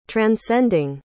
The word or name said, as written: transcending